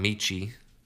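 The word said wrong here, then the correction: Metchie: Did I say it? Michie? – John Mechie. – Mechie. I'm not good at pronouncing it. No, you're good. Mechie's got it.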